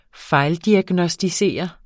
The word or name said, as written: fejldiagnosticere